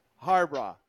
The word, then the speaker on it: Harbaugh: Jim Harbaugh.